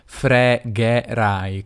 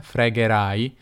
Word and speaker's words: fregherai: Notate per esempio come Mahmood dice fregherai con due e aperte, fregherai, e non dice fregherai che sarebbe diciamo l'accento standard italiano. Io come Mahmood direi nella vita di tutti i giorni fregherai con due e belle aperte.